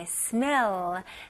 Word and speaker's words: smell: 네, smell. 네, 발음은 일단 이름절로 해주셔야 돼요. 그래서 smell 이런 식이 아니고요. smell. smell. Okay, very good.